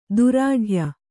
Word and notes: ದೃಢಾಯ: dṛḍhāya

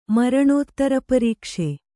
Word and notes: ಮರಣೋತ್ತರಪರೀಕ್ಷೆ: maraṇōttara parīkṣhe

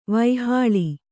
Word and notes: ವಾಹಳಿ: vāhaḷi

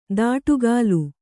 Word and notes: ದಾಟುಗಾಲು: dāṭugālu

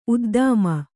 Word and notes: ಉದ್ದಾಮ: uddāma

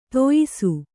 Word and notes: ದೋಯಿಸ: dōyisa

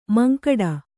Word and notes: ಮಂಕಡ: mankaḍa

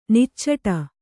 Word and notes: ನಿಚ್ಚಟ: niccaṭa

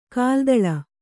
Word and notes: ಕಾಲ್ದೂಳಿ: kalḍūḷi

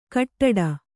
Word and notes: ಕಟ್ಟಡ: kaṭṭaḍa

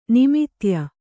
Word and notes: ನಿಮಿತ್ಯ: kannada

